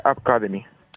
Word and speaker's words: App Academy: ¿App Academy o cómo habría que decirlo? – Bueno, realmente se llamaría App Academy.